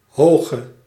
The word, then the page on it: positif: France; [pɔ.zi.tif]